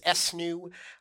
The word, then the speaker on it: ESNU: For us, we call it ESNU, which we sometimes pronounce SNU, where E is exemplary, S is satisfactory, N is not yet, and U is unassessable.